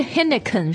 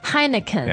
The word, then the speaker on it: Heineken: 不是 Heineken 是 Heineken